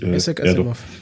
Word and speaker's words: Isaac Asimov: Wird ja wirklich so ausgesprochen? Ich weiß es gar nicht. Ist ja, ja eigentlich Isaac Asimov. Doch.